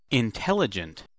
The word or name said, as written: intelligent